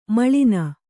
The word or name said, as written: ಮೇಲನ